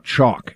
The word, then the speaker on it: Choq: If that's a double yes, I want to tell you about Chalk. It's spelled C-H-O-Q.